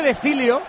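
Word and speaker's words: De Sciglio: No, se dice de Silio, dice Marcos. – El sonido glio del italiano no lo tenemos en el castellano y puede ser de Silio.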